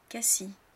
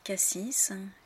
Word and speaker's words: Cassis: Beaucoup de Français se trompent et prononcent ces noms de villes de travers en disant Samoëns, Cassis, Chamonix, Avoriaz. Mais en fait, les gens qui vivent dans ces régions-là, dans ces villes-là, ne prononcent pas la lettre finale.